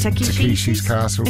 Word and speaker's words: Takeshi's Castle: Another word I can't pronounce. Tashiki's Castle. – That's Takeshi's Castle.